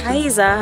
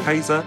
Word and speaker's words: Kiesza: Kaiser hat man, glaube ich, so ausgesprochen. – Ja, Kaiser. – Also man hat Kiesza hat es geschrieben.